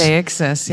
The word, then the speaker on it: Deixis: Deixis? – Deixis, yeah. – You said that a lot of writing that isn't great suffers from poor or lack of deixis.